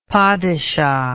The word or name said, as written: padishah